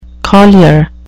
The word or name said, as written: collier